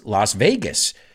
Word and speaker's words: Las Vegas: Now, you're saying, you just said it's Las Vegas. Sí, pero lo he pronunciado a la española aposta.